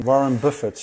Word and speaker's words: Warren Buffett: Hey, en eh, waarom die ja, interesse in Warren Buffett? Spreek het goed uit? Ja, spreek je, goed uit.